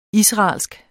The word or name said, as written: israelsk